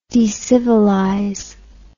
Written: decivilization

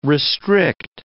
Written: restrict

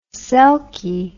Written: selkie

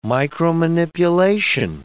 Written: micromanipulation

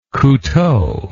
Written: couteau

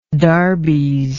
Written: darbies

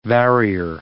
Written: varier

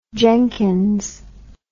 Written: jenkins

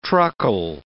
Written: truckle

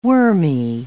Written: wormy

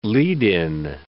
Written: lead-in

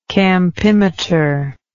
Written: campimetrical

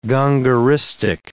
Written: gongoristic

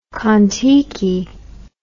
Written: Kon-Tiki

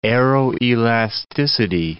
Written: aeroelasticity

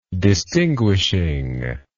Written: distinguishingly

